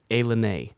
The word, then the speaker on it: Ilani: It's spelled I-L-A-N-I, but it's pronounced Alanay.